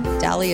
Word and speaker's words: Dalley: Dalley is spelled like Valley with a D.